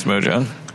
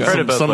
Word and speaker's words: Mojang: That's Mojang. – I've heard it pronounced like a million different ways. – Yeah, it's Mojang. Ways. – Sometimes it's by representatives of the company. – It's Mojang.